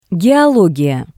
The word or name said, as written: Geologiya